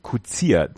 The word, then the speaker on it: Coetzee: Kutzier, Coetzee, wird Kutzier ausgesprochen.